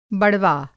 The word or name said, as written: बड़वा